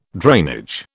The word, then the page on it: drainage: British English